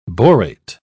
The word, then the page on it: borate: British English